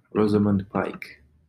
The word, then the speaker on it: Rosamund: Do I pronounce that name right? Let's see, Ro Zom. No. Rosamund Pike.